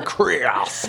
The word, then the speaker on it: Krios: You just say Chris as if you're a 90s underbite singer like James Hetfield or the guy from Creed or whatever. Krios.